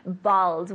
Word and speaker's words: bald: But bald, we say in English. Tell me. – Bald. – Good, good pronunciation of the D at the end. Ball, no, it's pelota. Bald, bald.